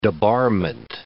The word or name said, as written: debarment